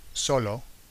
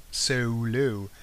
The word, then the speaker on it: solo: So when you get words in Spanish like solo, S-O-L-O, some English people pronounce it solo. I'm going solo. And what happens with that? There are probably 3 O's. A Spanish person would have to put 3 O's in each side of that word, you know, solo.